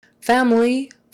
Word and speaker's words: family: Family, family history. Family, family. Make sure you get the L, not family. Family. Make sure your tongue comes up to the front.